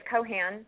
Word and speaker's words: Cohan: And they can just go to Facebook and search your name, Alissa, A-L-I-S-S-A, Cohan. Is it Cohan or Cohen? I don't know. I hope I haven't been saying it wrong. – No, that's what you do. It's Cohan. Thank you. – Okay. – Cohan with the A. Yeah. – C-O-H-A-N.